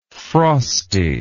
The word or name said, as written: frostless